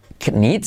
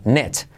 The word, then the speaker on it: knit: Eh, igualmente no se dice knit, sino net.